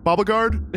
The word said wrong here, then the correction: Bellegarde: Bellegarde. – Bauble-guard. – It's Bellegarde.